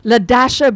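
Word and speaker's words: Lea-Dasha: LaDasha Brings Plenty. And her name is spelled L-E-A. And that dash ain't silent, assholes.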